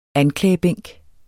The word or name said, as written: anklagebænk